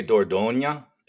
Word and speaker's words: Dordogne: As society teeters on the brink of nuclear apocalypse, Marcus Moksha, an oversexed alcoholic anthropologist, encounters Dordogne, is how I've been pronouncing it in my head. – Close enough. Right? It's technically Dordogne.